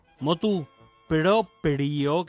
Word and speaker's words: motu proprio: Los latinismos peccata con dos Cs, peccata minuta, sub iudice con acento en la U, vox populi, motu proprio, que así se dice con la R entre la P y la I, aunque es complicada la pronunciación.